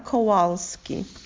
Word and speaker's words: Kowalski: My full name is Claudia Kowalski. – Please spell that. – K-L-A-U-D-I-A. And my last name, K-O-W-A-L-S-K-I.